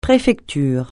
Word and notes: préfecture: francais

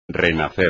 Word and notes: renacer: espagnol

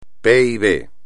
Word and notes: PIB: espagnol

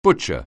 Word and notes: butcher: anglais

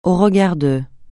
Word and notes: au regard de: francais

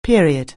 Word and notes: period: anglais

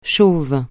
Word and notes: chauve: francais

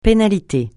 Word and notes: pénalité: francais

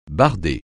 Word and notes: barder: francais

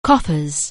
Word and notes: coffers: anglais

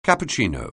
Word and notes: cappuccino: anglais

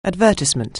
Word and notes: advertise: anglais

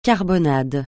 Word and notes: carbonade: francais

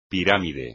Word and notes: pirámide: espagnol

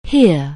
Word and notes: hear: anglais

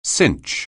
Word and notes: cinch: anglais

